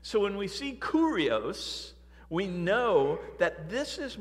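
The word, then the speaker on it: Kurios (κύριος): The Greek word is actually pronounced Kurios. Kurios.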